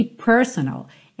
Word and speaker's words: personal: Essa palavra aqui, personal, ela é uma palavra interessante porque a sílaba tônica dela é a primeira, personal.